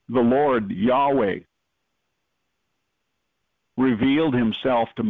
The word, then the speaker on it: Yahweh: Instead of YHWH, we put in the vowels in it and pronounce it Yahweh.